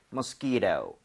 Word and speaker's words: mosquito: 뒤에 t라고 하는 부분은 모음 사이에 t가 있으면 flap이라고 해서 리을 비슷하게 발음이 됩니다. – 네. – 그래서 mosquito 해도 틀린 거 아니에요. 그런데도 부드럽게 mosquito 이렇게 하는 거 추천을 드리겠습니다.